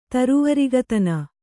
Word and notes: ತರುವರಿಗತನ: Kannada

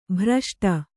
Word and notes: ಭ್ರಷ್ಟ: kannada

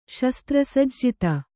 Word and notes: ಶಸ್ತ್ರಸಜ್ಜಿತೆ: śastra sajjite

